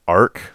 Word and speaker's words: ARQ: ARQ, apparently pronounced ARC.